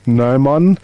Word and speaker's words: Gnomon: It's spelt G-N-O-M-O-N, Gnomon. And a gnomon is the part of a sundial that stands above the flat plane and casts its shadow.